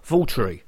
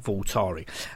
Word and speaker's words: Waltari: Apparently, for the last 21 years, I've been pronouncing the next band's name wrong. Not my fault, as the squirrel is the one who introduced me to the band in question, who are Waltari and not Waltari.